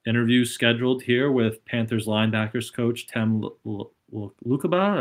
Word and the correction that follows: Lukabu: I think it's Lukabu, right? Lukabu.